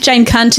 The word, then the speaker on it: Jane Cunt: Yeah, it's just pronounced how it's spelled. – Okay. – C-U-N-T. – Yeah, Cunt. Jane Cunt is my name.